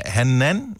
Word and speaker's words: Hanan: Hanan, måske udtales det fra København. Godmorgen, er det rigtigt sagt? – Korrekt.